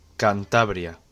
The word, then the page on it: Cantabria: Spanish